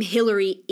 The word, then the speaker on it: Hillary: I'm so grateful that my name Hillary is only spelled with one L, because the double L of Hillary and the double L of Collier is just too matchy.